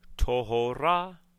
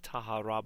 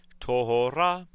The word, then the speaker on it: tohora: Teshev bidmehe tohora. – The word looks like tahara, but it is pronounced tohora.